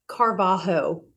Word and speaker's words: Carvalho: On the show, I have Frankie Carvalho.